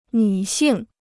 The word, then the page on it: 女性: nǚ xìng